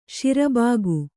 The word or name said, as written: ಸರಿಭಾಗ